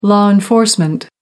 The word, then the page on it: law enforcement: American English